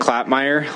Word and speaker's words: Klapmeier: My name is Dylan Klapmeier. Last name is spelled K-L-A-P-M-E-I-E-R, and I'm Governor Gianforte's education advisor.